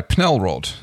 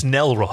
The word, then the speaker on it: Nelrod: Yes, this week's book is by Pnelrod, or... No, the P is silent. It's Nelrod.